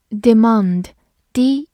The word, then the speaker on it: demand: demand，d e m a n d，demand，d e 发 d e d，m a n d，mand，demand，或者美式发音也可以读作 demand，它是一个名词，也是一个动词，表示要求或者需求。